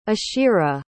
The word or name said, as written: ashira